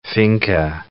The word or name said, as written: finca